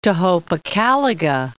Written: Tohopekaliga Lake

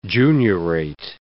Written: juniorate

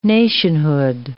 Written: nationhood

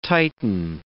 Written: tighten

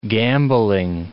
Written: gambling

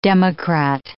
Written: democrat

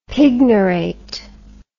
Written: pignorate

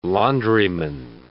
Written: laundryman

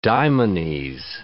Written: daimones

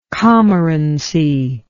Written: commorant

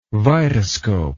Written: vitascope

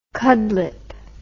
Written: cudlipp

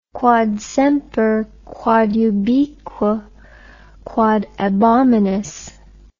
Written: quod semper quod ubique quod ab omnibus

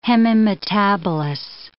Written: hemimetabolous

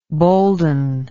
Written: bolden

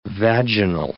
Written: vaginal

